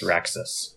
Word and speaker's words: Raxus: Am I saying that right? Raxus? – Yes, Raxus. – R-A-X-U-S. Okay. Raxus. – Raxus.